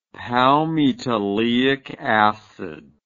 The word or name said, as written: palmitoleic acid